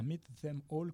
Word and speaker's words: Meet Them All: Donc, c'est Meet comme mythologie. Mm-hmm. Meet Them All comme Catch Them All, comme Kill Them All. Donc, Meet Them All, contracté, hein, Meet apostrophe E-M-A-L-L.